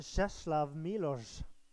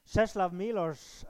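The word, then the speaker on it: Czesław Miłosz: Jeg tror at det er mer riktig å si som eh, den eh, polske forfatteren som heter for eh, Czeslaw Milosz. Ja, det er riktig uttalelse, til og med. Czeslaw Milosz han vant Nobelprisen i litteratur i 1980, og han skrev det her.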